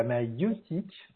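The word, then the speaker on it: maïeutique: Alors la maïotique, c'est pas de la mayonnaise, c'est la maïotique en fait. Ça s'écrit M-A-I-T-I-Q-E, je crois.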